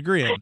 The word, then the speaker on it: Grehan: Is that how that's pronounced? Grehan? – Grehan? Yeah, Grehan. I think so. – Yeah, like Meehan. It would be Grehan.